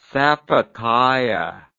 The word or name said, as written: sapucaia